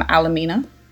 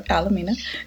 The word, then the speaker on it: Alameda: Is from Alameda. I said that right? – Yeah, Alameda.